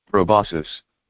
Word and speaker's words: proboscis: Let's see how that word is pronounced. – Proboscis. – Proboscis. Okay, that's the stinger.